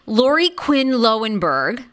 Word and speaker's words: Lauri Quinn Lowenberg: Lauri Quinn Lowenberg.